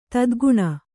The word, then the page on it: ತೆತ್ತಗಣ್: tettagaṇ